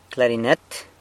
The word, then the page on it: clarinet: San Lucas Quiaviní